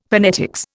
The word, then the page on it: phonetics: Indian